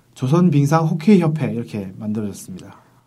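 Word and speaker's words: 호케이 (хоккей): 해방 이후에 둘 다 빙구라고는 쓰지 않는데 북한에서 호케이라고 이제 썼잖아요. – 아, 호케이. – 이게 러시아식 발음이라고 합니다. – 하, 아, 네. 키를 러시아시로 발음하면 호케이가 된다 이렇게.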